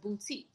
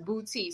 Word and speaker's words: Boutte: You either go to New Orleans, the New Orleans exit, or you go to, now the town is pronounced Boutte. So, there's no accent on the E or whatever. It's pronounced Boutte.